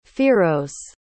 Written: firoze

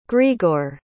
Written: greagoir

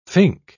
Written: fink

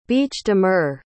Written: bêche-de-mer

